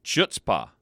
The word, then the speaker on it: chutzpah: H-A-T-S-P-H-A? – No. It's C-H-U-T-Z-P-A-H. – Wow. – Yep. It looks like chutzpah, but it's pronounced chutzpah.